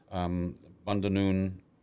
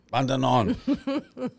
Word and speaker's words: Bundanon: Bundanoon, is that how you say it? – Bundanon. – I know, there's one less O there. I want it to be Bundanoon. – There is a Bundanoon. It's just not that one.